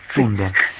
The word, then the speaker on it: Thunberg: Y quería preguntarte si estas últimas manifestaciones de los viernes, que en Italia las encabezó Greta Thunder o Greta Thunder, como no sé cómo sí. se pronuncia. – Thunberg, Thunberg. – Thunberg.